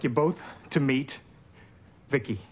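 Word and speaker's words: VICI: V-I-C-I, I pronounce it Vicki.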